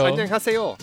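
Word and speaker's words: Annyeonghaseyo (안녕하세요): Annyeonghaseyo. – Annyeonghaseyo.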